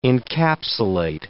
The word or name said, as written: encapsulate